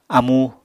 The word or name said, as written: amu